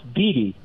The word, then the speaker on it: Beattie: My last name is pronounced Beattie.